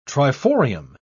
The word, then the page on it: triforium: British English